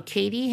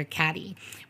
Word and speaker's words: Cady: So Katie Heron, but Katie spelled like C-A-D-Y. So the joke in the the story is they keep on calling her Katty.